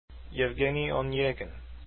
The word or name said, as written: evgeny onegin